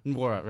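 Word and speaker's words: Nwora: It was a Jordan Nora. How do you pronounce that name? – Was it Jordan Wara? – Wara, right? And then just slammed it down over Enoch.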